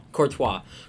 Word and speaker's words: Courtois: Courtois.